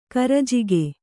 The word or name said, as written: ಕರಿಜಿಗಿ